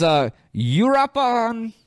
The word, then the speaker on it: Uruapan: It was Uruapan, Mexico. U-R-U-A-P-A-N.